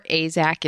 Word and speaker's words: AAZK: So Point Defiance AAZK, or AZAK if you want to, for some reason, pronounce AZAK. It instead of use the initials, is the Zookeepers Association.